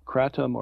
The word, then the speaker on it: Kratom: There's something called Kratom or KRATOM. I've seen it pronounced both ways.